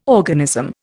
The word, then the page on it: organism: Indian